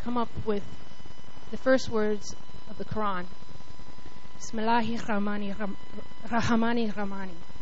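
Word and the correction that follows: Bismillahi Rahmani Rahim (بسم الله الرحمن الرحيم): Sorry, I said that wrong, didn't I? Bismillahi Rahmani Rahim. Sorry, thank you, Henry.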